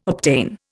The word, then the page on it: obtain: Indian